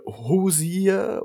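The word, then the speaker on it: Hozier: Äh, Hosier, Hosier mit. Hosier, ja, äh, ich habe hab gehört, ich habe es richtig ausgesprochen mit Hosier.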